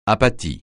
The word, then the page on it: apathie: francais